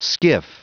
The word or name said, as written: skiff